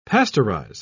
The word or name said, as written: pasteurize